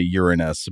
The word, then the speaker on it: Uranus: If you listen to astronomers and planetary scientists talk about this planet, most often you will hear them pronounce it Uranus. Basically the word urine and then the word us.